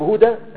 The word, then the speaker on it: هدى: فدي هتكتب هدى هاء ودال والف، لما توصل هتلغي الألف وتجيب التنوين، لو وقفت عليها رديت إليها الألف، فهي كتابتها في كل الأحوال بالألف، لكن عند النطق في الوصف هتبقى هاء ودال ونون، وعند الوقف هتبقى هاء ودال وألف، وفي كل الأحوال في الكتابة هتبقى هاء ودال وألف.